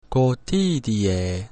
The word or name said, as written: cotidie